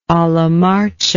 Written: alla marcia

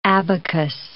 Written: abacus

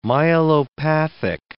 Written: myelopathic

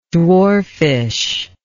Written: dwarfishness

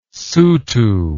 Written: sotho

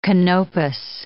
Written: Canopus

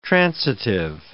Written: transitive

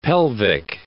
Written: pelvic

